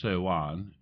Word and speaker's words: Sivan: Sivan, pronounced Sewan, is the third month, and is mentioned in Esther chapter 8, verse 9.